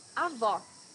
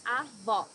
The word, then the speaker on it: avó: Avó, Grandmother. Here you need to hear the sound of the last O in the syllable. It should be a, uh, an open O, as we say in Portuguese. Avó.